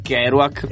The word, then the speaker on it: Kerouac: Esatto, Jack Kerouac.